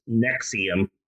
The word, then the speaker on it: NXIVM: And a few years in, he changes the name of his company from the Executive Success Program to NXIVM, which they pronounced Nexium.